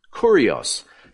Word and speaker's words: kurios: There is the word kurios, kurios, Kappa Upsilon, and the thing that looks like a small p is actually the letter R. That's the Greek letter rho. And then the O is a, a, a short O. It's Omicron. And it ends with the S, the sigma, kurios.